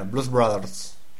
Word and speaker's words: Blues Brothers: Quindi festeggiamo la vittoria dei Tampa Bay Buccaneers e andiamo in pausa con la seconda canzone di quest'oggi, canzone dei Blues Brothers, giusta la pronuncia, giusta che è molto americana, Blues Brothers.